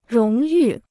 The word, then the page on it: 荣誉: róng yù